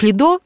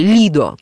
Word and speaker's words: Лидо: Ты наверное, обозначить остров Лидо, известный, знаменитый, или Лидо, как его называют итальянцы, для нас привычное ударение на последний слог,